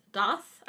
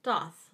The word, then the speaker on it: Thoth: Okay, so the first one is Thoth, I think is how you pronounce Thoth. That. It's spelled T-H-O-T-H.